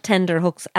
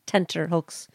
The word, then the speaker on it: tenterhooks: It's not tenderhooks as commonly used in the English language. – We've had this before. – Tenterhooks. – Yes. Tent. So like tent or hooks. – That's how you spell it. T-E-N-T-E-R.